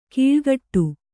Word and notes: ಕೀಳ್ಗಟ್ಟು: kīḷgaṭṭu

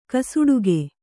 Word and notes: ಕಸೊಡಿಗೆ: kasoḍige

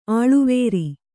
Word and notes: ಅಳವರಿ: aḷavari

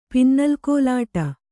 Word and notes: ಪಿನ್ನಲ್‍ಕೋಲಾಟ: pinnal kōlāṭa

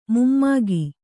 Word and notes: ಮೊಮ್ಮಗ: mommaga

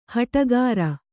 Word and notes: ಹಟಗಾರ: haṭagāra

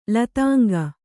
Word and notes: ಲತಾಂಗಿ: kannada; latāngi

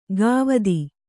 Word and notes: ಗಾವದಿ: gāvadi

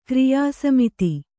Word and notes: ಕ್ರಿಯಾಸಮಿತಿ: kriyā samiti

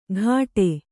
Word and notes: ಘಾಟೆ: ghāṭe; Kannada

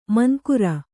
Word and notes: ಮುಂಗುರಿ: munguri

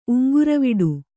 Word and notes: ಉಂಗುರವಿಡಿ: uŋguraviḍi

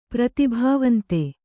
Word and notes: ಪ್ರತಿಭಾವಂತೆ: pratibhāvante; Kannada

